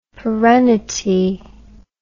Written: perennity

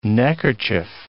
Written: neckerchief

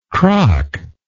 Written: crock

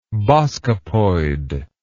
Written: boskopoid

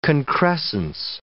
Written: concrescence

